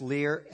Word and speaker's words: Leiux: We got there, we learned that a young senior girl, she's a cheerleader, her name was Kelsey Lear, and I believe the last name is spelled, though funny, with ends with an X, right, I believe? L-E-I-U-X. And it's pronounced Lear.